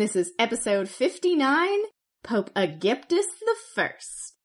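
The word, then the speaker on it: Agapetus: And as we can tell by saying he is the first, there will be another Aegyptus, and I will stick with this pronunciation because it works for me. And I have come to this conclusion kind of how some languages say the word Egypt. So, yeah, we're going with Aegyptus, and that is it.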